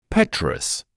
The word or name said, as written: petrous